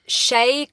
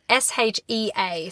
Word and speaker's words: Shea: So 2017 into 2018 we follow Shay Collins, whose name is spelled S H E A. So Shea.